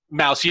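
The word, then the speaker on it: Maus: I got assigned, and I don't know if it's supposed to be pronounced Mouse or Moss or... – I say Mouse. – I read Mouse.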